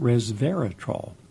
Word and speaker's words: resveratrol: From Nanjing Medical University in China comes a study about resveratrol. Res, R-E-S, Vera, V-E-R-A, trol, three syllables, resveratrol.